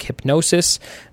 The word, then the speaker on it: Hipgnosis: That would be, let's see, it says pronounced like hypnosis, so it's hypnosis, but it's spelled H-I-P-G-N-O-S-I-S.